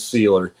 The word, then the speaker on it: Sieler: S-I-E-L-E-R, Seiler. Yeah. I think they're pronouncing it Seiler.